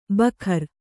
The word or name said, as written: ಭಕಾರ